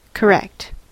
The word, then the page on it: correct: American English